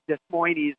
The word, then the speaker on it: Des Moines: Why is it Des Moines and why is it Des Plaines? – Right. – Has anyone ever figured this out? – I think it's because nobody wants to pronounce it Des Plaines. Because that's how you would pronounce it if you were following those rules.